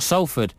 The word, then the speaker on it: Salford: Salford, I've been told by Salford, that's how you pronounce it, Tim Armitage, who's, I think he's from up north.